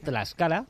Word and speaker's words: Tlaxcala: pasta en Tlaxcala.